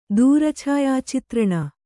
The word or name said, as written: ದೂರಛಾಯಾಚಿತ್ರಣ